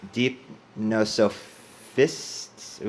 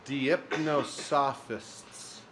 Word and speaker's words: Deipnosophists: It's probably Socrates, and it was Deipnosophists. Ooh, try and say that word. – Don't give it to me. I was very scared when you were coming closer to me with that paper. – Deipnosophists.